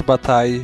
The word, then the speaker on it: Bataille: É, Jorge Bataille.